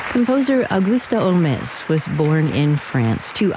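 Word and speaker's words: Holmès: Originally pronounced her last name like Sherlock Holmes, but Frenchified it to Olmes after becoming a citizen of France at the age of 24.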